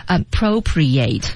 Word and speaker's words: appropriate: appropriate 就是后面那个 i 变成小 r 的音，要把它发出来。